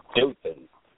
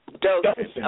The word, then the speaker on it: Dothan: Am I pronouncing that correct? Is it Dothan or Dothan? – Dothan. – Dothan, okay. Dothan.